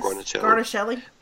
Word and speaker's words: Guarnaschelli: Our favorite Chopped judge is Alex Guarnaschelli. Is that how you say it? – Yes. Guarnaschelli.